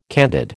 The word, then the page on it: candid: American English